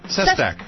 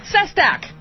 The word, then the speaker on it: Sestak: Representative Joe Sestak. – Sestak, yes.